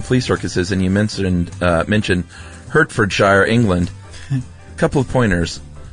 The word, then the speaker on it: Hertfordshire: Hertfordshire is pronounced Hartfordshire. – Oh, we were way off. – I admit it, it isn't spelled H-A-R-T, but then that is just the English language for you.